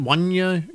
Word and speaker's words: Juanya: 26-year-old Wanya Gray. Is that how you pronounce it? Wanya Gray. J-U-A-N-Y-A. Wanya Gray.